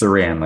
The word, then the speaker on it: Saran: It's Saran, like Saran, saran wrap. Like Saran wrap.